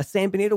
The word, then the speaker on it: San Benito: And one of them was that you would force people to wear a San Benito. Am I pronouncing that correct? – Yes. – A San Benito.